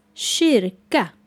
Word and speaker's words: kyrka: The next word is shirka. Shirka. It is spelled K-Y-R-K-A.